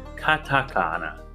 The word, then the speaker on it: katakana: His name was localized as Mars, which was put down due to the vague pronunciation of the katanaka or katana, kat, katakana, katakana.